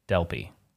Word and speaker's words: Delpy: Delpy, Delepi. I can pronounce it however I do please. It's Delpy.